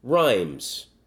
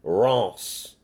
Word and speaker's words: Reims: And the French say it's spelled R-E-I-M-S. – Yes. – Rhymes. But the French pronounce it Reims.